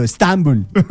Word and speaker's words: Estambul: Nosotros le ponemos el acento en otro lado. Estambul. Así le decimos nosotros, los más cercanos a esa tierra.